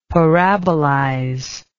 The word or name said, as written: parabolize